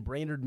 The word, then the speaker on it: Brainerd: We are currently in Brainerd, Minnesota. Did I say that right? – You did. – Okay. Yep. Every time that I've had to like think about it, I've been like, okay, brain and nerd, put those together.